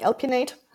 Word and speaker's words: Alpin8: Das heißt, jetzt hoffe ich, dass ich das richtig ausspreche, Alpine Aid. Oder ja, genau. Also wir ziehen es zusammen, wir sagen Alpinate, okay. aber geschrieben Alpin mit einer Ziffer 8 wie man es jetzt letztlich ausspricht, ist auch eigentlich egal.